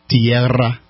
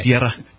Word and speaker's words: Tierra: Would you like to roll your R's for this one? – Do you always have to roll your R's with this? – It's double R. – Yeah. – Tierra.